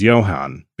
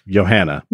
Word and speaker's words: Johanne: At first I thought it was Johan because it's spelled Johanne. I was like, oh, I've never seen Johan spelled that way. – Joanna. – It's Joanna. – Yeah. – But it's Danish, so it's Johanna.